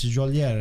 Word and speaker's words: Geolier: That's how you say it? Juliet? Juliet. That's French. – He is French. – Is he French? – No, he's Neapolitan. Yeah. But it's Juliet. It's, how do you spell it? With a W? – G-E-O-L-I-E-R.